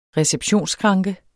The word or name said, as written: receptionsskranke